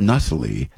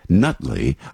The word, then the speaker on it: Nutley: I remember being at Aunt Louise's house in Nutley, and you got to pronounce that right. It's not Nutley, Nutley.